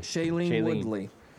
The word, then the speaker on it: Shailene Woodley: Shailene Woodley.